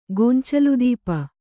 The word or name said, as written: ಗೊಂಚಲುದೀಪ